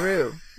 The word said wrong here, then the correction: Ryu: Ryu. Jun-yo.